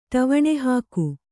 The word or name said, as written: ಟವಣೆಹಾಕು